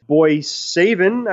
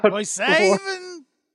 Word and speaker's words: Boissevain: So on September 15th and September 22nd in their local arenas, Boissevain, I believe, is how you pronounce the uh, the city, which I did look up, by the way.